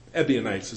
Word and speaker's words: Ebionites: But there was a group known as the Ebionites is a better pronunciation, derived from the Hebrew Ebionim, meaning the poor or the poor ones.